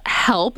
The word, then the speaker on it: Healp: It's called Healp, but it's spelled like the word health with a P. So H-E-A-L-P.